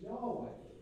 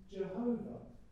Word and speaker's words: Yahweh: The nearest we've got in trying to understand the Jewish pronunciation is Yahweh. In the West we use Jehovah.